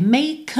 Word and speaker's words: make: O som termina na letra K. No caso aqui, a gente está na primeira linha, a gente vai ter make a wish. Eu já vou juntar o make no A, make a wish, mas eu não vou pronunciar essa letra E, tá?